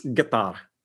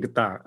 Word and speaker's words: Qatar: And I know I'm saying it wrong because I watched this YouTube video about how to pronounce Qatar. Is it Qatar? Is it Qatar? – Right, right. – And the guy on the video is like, it's Qatar. And like, I'm not saying Qatar.